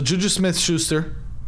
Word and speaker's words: JuJu Smith-Schuster: So Juju Smith-Schuster. Did I say that right? – You did say it right.